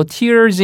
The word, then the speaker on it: tear: 네, Eric Clapton인데 그 Eric Clapton의 노래 바로 Tears in Heaven. 똑같은 스펠링인데 tear가 아니고 얘를 발음만 바꾸면 찢다가 돼요. – Torn, tear. – Tear죠, tear. – T-E-A-R, tear.